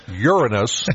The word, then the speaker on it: Uranus: Yes, Uranus, not the other pronunciation.